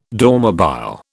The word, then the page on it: Dormobile: British English